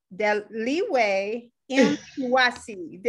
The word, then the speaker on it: Deliwe Mtungwazi: Her name is Deliwe Mtungwazi.